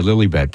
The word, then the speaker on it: Lilibet: I'll tell you, Lilibet, that is fun to say, though, isn't it? – It sure is.